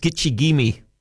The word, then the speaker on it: Gitche Gumee: It's from the Ojibwe. Pronounced Gitche Gumee, meaning great sea.